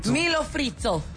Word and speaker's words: milho frito: Milho frites. No. Am I saying it right? – Close. Yeah, sure. – How do I say it? – Milho frito. Yeah.